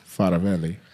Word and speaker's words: Faravelli: It's Italian, so Faravelli. – Faravelli.